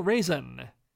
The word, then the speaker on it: Reesen: Let's begin with Emil Rasen, whose name, as I'm pronouncing it, sounds like a shriveled grape, but it's spelled R-E-E-S-E-N.